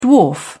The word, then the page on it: dwarf: British English